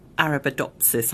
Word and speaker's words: Arabidopsis: So where exactly are your Arabidopsis, have I pronounced that right, seeds on Artemis? – So you pronounce it really well.